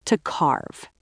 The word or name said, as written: to carve